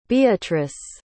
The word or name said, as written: beatrice